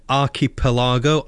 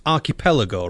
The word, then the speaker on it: archipelago: She also said archipelago. I think it's pronounced archipelago, right? – Exactly. – I got it right the second time.